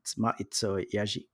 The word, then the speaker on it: Ma'iitsoh Yazhi: But it's Maizo Yazhi would be the correct way. But I've heard everything from Master Yahtzee to Machu Picchu.